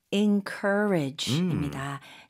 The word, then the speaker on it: encourage: Encourage입니다. 스펠링은 EN으로 시작하지만 발음은 IN으로 하시면 좋아요. – 아. – 왜냐면 강세가 cur에 들어가요.